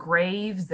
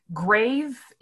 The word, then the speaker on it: grave: I also just learned from doing some original pronunciation work that grave used to rhyme with have, so it was grav.